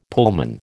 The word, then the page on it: Pullman: American English